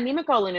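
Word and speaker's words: Nemacolin: We saw when Ashley did, is it Nima Colon? Is that how you say it? – Yeah, Nima Colon in Pennsylvania.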